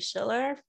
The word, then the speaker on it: Schiller: You can find me on LinkedIn at Hayley Schiller. For those of you who don't know, it's spelled H-A-Y-L-E-Y, last name S-C-H-I-L-L-E-R.